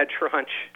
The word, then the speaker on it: trunch: Yeah, trunch. That's right. this is like rhymes with lunch.